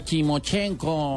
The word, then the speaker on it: Timochenko: También vamos a tener dedicatoria de Chimotenco. No, sí, así es, ¿no? Se pronuncia así. Hmm. No, no, Timochenko.